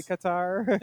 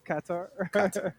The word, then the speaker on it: Qatar: I actually don't know, but I know that that's how you're supposed to pronounce Qatar as well. It's not really Qatar. – Yes. – It's Qatar. – Yeah, just okay. Take out the second A. And then just pronounce it in bad Jamaican, and you're there.